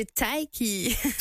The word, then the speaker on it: Taïk: Taïk de Taïki. – Taïk, donc. – Taïk comme Mike, mais avec un T.